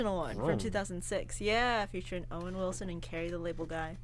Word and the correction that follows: Larry the Cable Guy: Larry the Cable Guy.